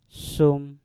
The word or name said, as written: sum